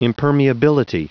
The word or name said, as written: impermeability